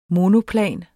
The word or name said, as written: monoplan